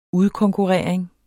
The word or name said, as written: udkonkurrering